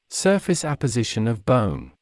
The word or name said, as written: surface apposition of bone